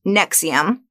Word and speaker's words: NXIVM: Well, first of all, it's pronounced Nexium, like the heartburn medication.